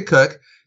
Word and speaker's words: Koch: My name is Kathy Cook. It's spelled K-O-C-H. So I used to teach second graders. It was a great spelling lesson about how the rules don't work.